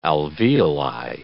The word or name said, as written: alveoli